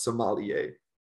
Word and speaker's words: sommelier: So it's actually pronounced sommelier, but you sommelier. Can, I've heard people, sommelier, okay. But I've heard people pronounce it sommelier. So okay. Either one is perfectly fine.